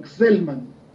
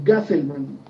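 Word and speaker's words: Gsellman: Ellos están utilizando un lanzador que es de apellido, es muy difícil pronunciarlo, Gselman. – Sí. – O Gaselman, pero sí. La y la S están seguidas.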